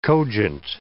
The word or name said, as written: cogent